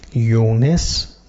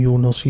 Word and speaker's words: یونس: و ان یونس لمن المرسلین، نامی که اینجا مطرح میشه کلمه یونس، یونس تفاوتی نمی کند در تلفظ، اگر بگوییم یونس یا یونس هر دو صحیح است، مانند یوسف و یوسف.